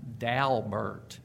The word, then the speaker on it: Daubert: My principal contribution here is to report that the folks who brought the case to the Supreme Court pronounced their name Daubert, or as some might say, exactly as it's spelled.